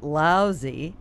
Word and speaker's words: Laozi: Of course, Sam's ice cream is anything but Laozi. – It's spelled L-A-O-Z-I and pronounced Laozi, L-O-U-S-Y.